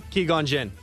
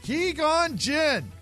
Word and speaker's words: Qui-Gon Jinn: How do you say that name? – Qui-Gon Jinn. – Qui-Gon Jinn. – Qui-Gon Jinn.